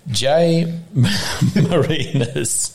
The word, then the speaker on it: Jay Marinis: Jay Marinis.